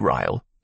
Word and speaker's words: Ryle: An impressed and incredibly gullible Freights admitted Ryle, it rhymes with smile, as a 50-50 partner.